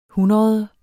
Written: hundrede

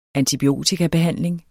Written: antibiotikabehandling

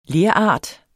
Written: lerart

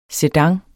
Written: SEDANER

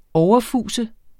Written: overfuse

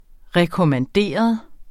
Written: rekommanderet

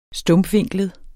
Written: stumpvinklet

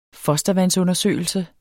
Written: fostervandsundersøgelse

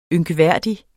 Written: ynkværdig

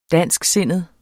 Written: dansksindet

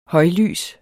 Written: højlys

